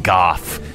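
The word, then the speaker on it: golf: At number 7, Goff. That's the way they pronounce it in Scotland without the L.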